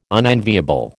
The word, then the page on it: unenviable: American English